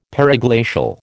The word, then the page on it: periglacial: American English